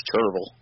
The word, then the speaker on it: Cherryville: Well, I grew up in a little town in North Carolina called Cherryville, but it's pronounced Cherville. The Y is silent.